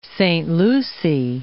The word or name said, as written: Port Saint Lucie